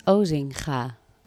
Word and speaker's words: Osinga: it's Osinga. That's official, as in O S I N G A. So how would you pronounce that? – Ozinga. – Ah, yeah, but you know the Dutch. So lots of people will say like Ozinga.